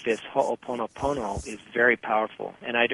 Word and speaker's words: Ho'oponopono: It's Ho'oponopono. I know how to say it. That's all. So it's Ho'oponopono.